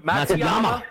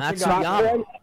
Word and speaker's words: Matsuyama: I got uh, Hideki Hideki and um, Matsuyama. Got uh, Matsuyama.